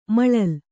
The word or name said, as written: ಮಲ್ಲಲಿ